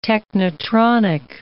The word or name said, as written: technetronic